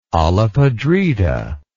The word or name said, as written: olla podrida